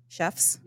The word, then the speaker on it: Szewc: I said your name right, Szewc? – Yep.